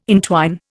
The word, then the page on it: intwine: Indian